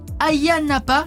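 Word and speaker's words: Ayia Napa: à Hagia Napa, je prononce bien ? – Oui, c'est ça.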